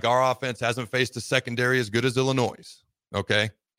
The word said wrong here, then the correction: Illinois: I just said Illinois'. Illinois where the S is silent.